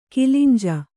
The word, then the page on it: ಕಿಲಿಂಜ: kilinja